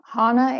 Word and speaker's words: Hannah: Am I pronouncing, is it Hannah or Hanna? Did I pronounce that wrong the whole time? Hanna, I apologize.